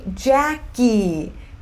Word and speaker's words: Jackie: Meu nome é Jackie. Jackie tem duas sílabas. E eu recebo mensagens todos os dias com as pessoas me chamando de Jack. Jack.